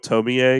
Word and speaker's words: Tomie: I'm going to go with Tomie because that was the one that was the most common one. But it's spelled Tomie. Tomie.